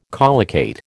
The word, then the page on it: collocate: American English